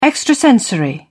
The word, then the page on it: extrasensory: British English